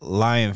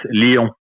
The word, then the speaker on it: Lyon: And how do you, and like I pronounce it Lion France, how do you pronounce it? – For us, it's Lyon.